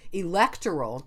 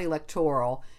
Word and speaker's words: electoral: Okay, first I'm going to say pronunciation-wise, it technically, usually, the original things was it's electoral, not electoral, and mayoral, not mayoral. That's more recent, the stress on the or part.